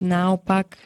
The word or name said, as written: naopak